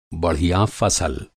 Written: बढ़िया

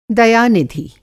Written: दया-निधि

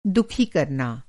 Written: दुखी

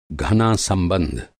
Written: घना

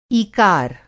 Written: ईकार

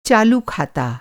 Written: खात

